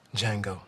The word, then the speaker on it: Django: Django. The D is silent. – The D is silent. – Indeed it is.